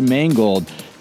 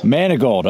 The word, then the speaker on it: Manegold: I'm here with Ty Manegold. Ty, first of all, did I pronounce the last name correctly? – Close. Manegold. – Manegold.